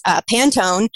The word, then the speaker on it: Pantone: One thing I thought was really interesting was Pantone. That's pronounced correctly, right? – Yeah.